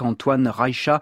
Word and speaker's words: Antonín Rejcha: dans ce quatuor en ré majeur opus 12 d'Antonín Rejcha, comme l'on dit en tchèque, Antoine Rejcha, prononciation qui a divergé à son époque, alors elle divergera au cours de ces deux émissions.